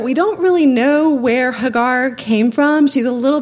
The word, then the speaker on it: Hagar: Most English pronunciations pronounce her name Hagar, but for the sake of honoring this woman, I will attempt to use the Hebrew pronunciation of her name, Hagar.